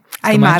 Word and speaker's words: Aymara: Aymara.